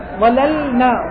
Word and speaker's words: ظللنا: ظللنا، ظللنا تحتاج إلى لطف في النطق يتضح معه اللام.